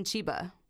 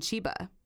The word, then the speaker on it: Chiba: It is in Chiba. What? Say that again. Can you spell it? In Chiba. I spell it. C-H-I-B-A.